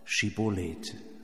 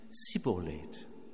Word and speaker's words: Schibboleth: Sie müssen das Wort Schibboleth sagen. Das hebräische Wort kennt dafür verschiedene Bedeutungen. Strom, Fluss, Kornähre, Ölzweig. Letztlich ist es fast egal. Die Herausforderung liegt in der Aussprache dieses Wortes. Denn so wie der Stadtbremer über den Spitzenstein gestolpert ist, so konnten die Ephraimiter das Wort Schibboleth nicht sagen. Sie sagten stattdessen Sibboleth.